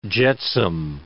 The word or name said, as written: jetsam